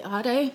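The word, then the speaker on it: Aire: Is it pronounced Ade? – Yeah. – Did you just say Ade? – That's what it is. When we walked in, I was saying air, but when we went to the New York one the first time, the girl said that. And then we went to Spain. I was like, oh, this isn't a coincidence. That's how they're pronouncing this. – Wow. – Yeah. – We're ghetto. – I've been out here saying air.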